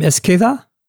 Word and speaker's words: Esqueda: How do you say your last name? Esqueda? Am I saying it right? – Perfect. All right. That is the most perfect way you could pronounce it.